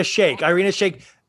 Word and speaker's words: Shayk: And we got the big news story this past week that Kanye, for his birthday, him and Irina Shirik, I don't know how to say her name. – Irina Shayk.